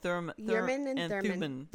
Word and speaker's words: Thummim: Urim and Thummim. Because there's no R in Thummim, so Urim and Thummim.